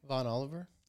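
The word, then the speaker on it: Vaughan Oliver: Von Oliver? – Yes. Thank you for the authentic pronunciation.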